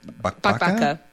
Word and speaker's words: bakbaka: There's another recipe, and boy, I'm not confident that I will pronounce this correctly, but it's the one pan bakbaka. – Bakbaka. Yeah, bakbaka. Yeah, that's it. You did well.